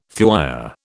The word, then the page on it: thuya: British English